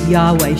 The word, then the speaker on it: Yahweh: God gives his name in Isaiah 42:8, YHWH, usually pronounced as Yahweh.